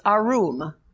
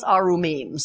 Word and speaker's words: arum: It's arum. If you want to spell it, it's A-R-U-M. It's an easy way of dealing with it. When it's plural, it's arumim.